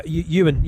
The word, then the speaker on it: Ewan: Is that Owen in the eighth place there? – Yeah, Ewan. – Ewan, apologies. – That's you. Ewan's a Manx name. Ewan.